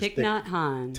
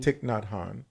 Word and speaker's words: Thich Nhat Hanh: No Mud, No Lotus by, I'm going to say his name not correctly, but it's Thich Nhat Hanh. – Thich Nhat Hanh.